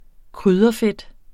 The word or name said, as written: krydderfedt